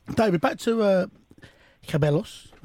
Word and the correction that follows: Ceballos: was that what I say his name? – Yeah. Ceballos.